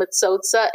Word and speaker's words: Matsotsa: So Hermence and Matsotsa, M A T S O T S A.